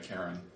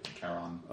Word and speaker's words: Charon: I was going to go and find a dark alley and see about talking to Karen. Charon.